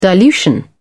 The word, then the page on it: dilution: British English